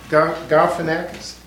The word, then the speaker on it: Galifianakis: Galifianakis.